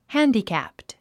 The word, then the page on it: handicapped: hǽndikæ`pt; ハァンディキャップト